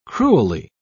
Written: cruelly

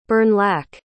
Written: Bernlak

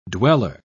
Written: dweller

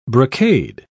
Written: brocade

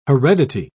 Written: heredity

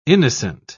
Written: innocent

